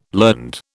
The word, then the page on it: learned: British English